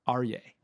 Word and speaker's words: Aryay: And I'd like to welcome today's guest, Aryay.